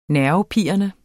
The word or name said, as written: nervepirrende